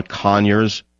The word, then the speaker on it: Conyers: You know John Conyers, the quiet, soft-spoken John Conyers, with an emphasis on the first syllable of his last name, John Con.